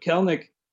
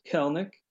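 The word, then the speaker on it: Kelenic: And it is pronounced Kelnick. Hate to be a stickler, but we hate a stickler, don't we, folks? But it is pronounced Kelnick. You can check it out on his baseball reference page. So maybe you can impress your friends before everybody else catches on and starts pronouncing his name correctly.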